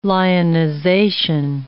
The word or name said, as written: lionization